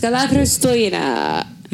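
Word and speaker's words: Kalla Christoina (Καλά Χριστούγεννα): Kalla Christoina. That's how you say it in Greek.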